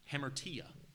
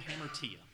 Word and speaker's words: hamartia: That word sin that's used there, the very last word of verse number 9, that is the Greek word, make sure I say this right, hamartia. That's the word hamartia.